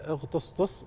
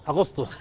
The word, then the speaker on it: أغسطس: ألف مبروك لأدي هو أخونا وأختنا، لكن أغطسطس انطقوها يا شباب أغسطس، الله يبيض وجهك فزعتني هاي تطلع اليوم خمشة وأغسطس وجهت صح اليوم اللي صار رايح فيها.